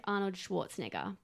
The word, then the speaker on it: Schwarzenegger: Think Arnold Schwarzenegger. Pretty sure I pronounce that wrong every time. – That was right.